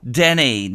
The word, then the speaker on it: Denis: The original song was Denise, D-N-I-S-E. They dropped the E and they made the S silent a la Francais, pronouncing it Denny.